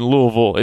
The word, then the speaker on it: Louisville: I've learned there's a bunch of different ways to pronounce it. You can walk by the visitor center here. They got a whole sign out there with the different pronunciations. But I've been told that if you say anything other than Louisville, it's a pretty clear indication that you're not from around these parts.